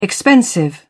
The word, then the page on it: expensive: British English